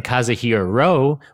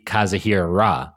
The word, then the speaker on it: Kazuhira: I've been saying Kazuhiro Ro when it should be Kazuhiro Ra.